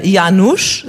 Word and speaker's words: Janusz: Janusz, das ist also ganz normal wie unser SCH.